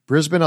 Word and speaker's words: Brisbane: Did I actually pronounce it correctly? – That was wonderful because we often get Brisbane, which gives us a little bit of a laugh because we know, uh-oh, they're from overseas.